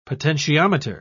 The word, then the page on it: potentiometer: British English